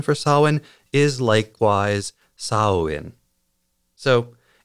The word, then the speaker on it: Samhain: First, while the holiday I'm talking about is spelled as though it would be pronounced Samhain or Saman, it's actually pronounced Samhain or Samhain.